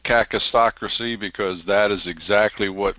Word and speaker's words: kakistocracy: But we live in a cacistocracy, and you spell it K-A-K-I-S-T-O-C-R-A-C-Y, cacistocracy.